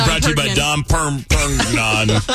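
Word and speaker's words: Dom Pérignon: Wait, what's it called again? I'm sorry, I've always been pronouncing it incorrectly, apparently. What is it? – Dom Pernin. Pernoning. – Anonymous. Dom Perignon? – Yes.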